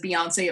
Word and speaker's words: Beyonce: Beyonce's always been wonderful, and we met her so early that when she introduced herself, she's introduced herself as Beyonce. It rhymes with fiance.